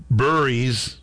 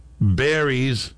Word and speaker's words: Burries: I can't count the amount of times I heard radio hosts over the last couple of days call Braden Burries, Berries, or Burris.